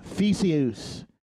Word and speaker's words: Theseus: Uh, I pronounced it wrong. Theseus. It's Greek. Okay, well, explain that to me.